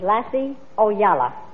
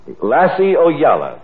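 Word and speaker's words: Lasse Ojala: His name is Lassie Oyala. – Lassie Oyala. Thank you. Here he is. – Thank you. – Lacio Yala. That's spelled L-A-S-S-E, capital O-J-A-L-A.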